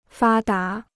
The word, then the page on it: 发达: fā dá